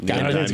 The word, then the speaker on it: Gaiman: Gaiman.